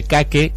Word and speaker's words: Cake: Se llama Cake, la banda. Suena, se escribe Cake con C-A y K-I-E. – Como un pancake. – Como un cake.